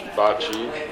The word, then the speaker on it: Bacci: My name is George Bacci. That's how you pronounce it. Some people pronounce it Beatty in American United States.